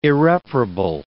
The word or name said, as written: irreparable